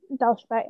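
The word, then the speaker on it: Dal Shabet: Oh, yeah, Dalshabet, yeah.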